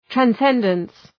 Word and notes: transcendence: træn’sendəns

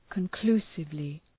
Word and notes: conclusively: {kən’klu:sıvlı}

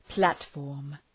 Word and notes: platform: ‘plætfɔ:rm

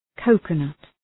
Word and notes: coconut: ‘kəʋkənʌt

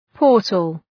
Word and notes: portal: ‘pɔ:rtəl

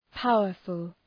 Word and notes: powerful: ‘paʋərfəl

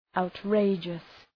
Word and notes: outrageous: {,aʋt’reıdʒəs}

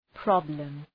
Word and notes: problem: 'prɒbləm

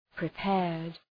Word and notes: prepared: prı’peərd

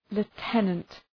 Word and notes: lieutenant: lu:’tenənt

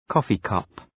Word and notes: coffee cup: ‘kɔ:fıkʌp

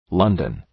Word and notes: London: ‘lʌndən

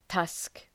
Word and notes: tusk: tʌsk

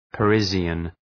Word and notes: Parisian: {pə’rıʒən}

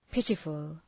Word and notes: pitiful: ‘pıtıfəl

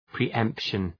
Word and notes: pre-emption: pri:’empʃən

